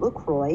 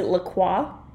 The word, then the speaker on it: LaCroix: The passion fruit LaCroix is pretty damn good. – See, I actually pronounce it LaCroix. – LaCroix, because it's French, but I know everyone calls it LaCroix.